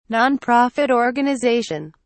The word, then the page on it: nonprofit organization: British English